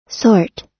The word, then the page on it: sort: American English